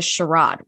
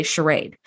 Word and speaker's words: charade: Riker says, I'm done playing this charade, which is the British pronunciation of the word that we say charade.